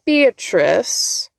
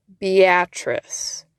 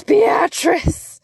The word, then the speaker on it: Beatrice: In episode 622, you did a really cool segment on a place that you pronounced Beatrice, but here in Nebraska, we call it Beatrice. I really enjoyed it. I think it's awesome. But I also had the internal screaming of like, it's Beatrice, which is fine.